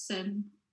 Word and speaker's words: SYN: Yeah, it's pronounced SYN, it's like an Icelandic term. Yeah. And as people don't know how to pronounce it, I didn't know when I first worked for them either, but yeah, it's SYN.